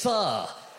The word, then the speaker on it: pho: Now he's at work like, it's not pronounced pho, Valerie. It's pho.